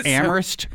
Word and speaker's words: Amherst: And you're from Ohio where they do pronounce the H for the Amherst. – Mm-hmm. So Amherst, Mass, is no H.